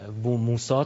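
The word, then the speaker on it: بوموسی: جالب، رهبر انقلاب هم خیلی تاکید دارن که اینجا بوموسا تلفظ بشه.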